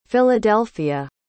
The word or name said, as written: philadelphia